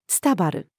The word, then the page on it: スタバる: female